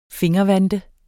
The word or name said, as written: fingervante